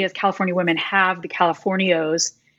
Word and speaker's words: Californios: Except that what about the women who are Californios? How did you say that in the book? Those Californios.